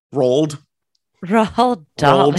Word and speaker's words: Roald Dahl: Roald Dahl. He was like my favorite author. – Yeah. The BFG. – No, Ooh. Actually, I'm sorry. Tommy DiPaolo is my favorite author, but Roald Dahl comes in close second.